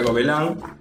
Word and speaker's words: Gobelins: Se escribe Gobelin, se pronuncia Gobelin. Si quieren aprender un poco de pronunciación de francés.